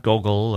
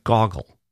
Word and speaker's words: Gogol: and say that Nabokov also wanted to correct the American pronunciation of Gogol, and say it's not Gogol or Gogol, but Gogol.